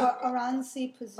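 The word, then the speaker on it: Oranssi Pazuzu: Orasani Pazuzu. – How do you pronounce it? – Oransi Pazuzu.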